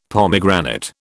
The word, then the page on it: pomegranate: British English